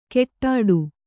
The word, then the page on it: ಕೆಟ್ಟಾಡು: keṭṭāḍu